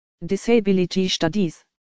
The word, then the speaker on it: Disability Studies: Disability Studies spricht man so, Disability Studies.